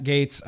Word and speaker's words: Gaetz: Matt Gaetz. Yeah, also Republican from Florida. Gaetz. But, oh, is it Gaetz? I'm sorry, Matt Gaetz. I've been saying your name wrong.